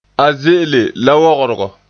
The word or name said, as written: ze'ele